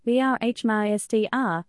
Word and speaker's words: Brahmastra: Brahmastra, Part 1, Shiva, pronounced Brahmastra, is a 2022 Indian Hindi language fantasy action adventure film written and directed by Ayan Mukherjee.